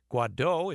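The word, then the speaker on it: Guaidó: Juan Guaido, if I'm getting his name correctly.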